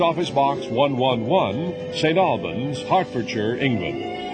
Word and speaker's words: Hertfordshire: That's pronounced Hertfordshire.